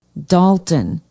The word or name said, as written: Dalton